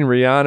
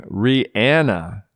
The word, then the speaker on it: Rihanna: Now, according to many, including Rihanna herself, she actually pronounces her name Rihanna, not Rihanna.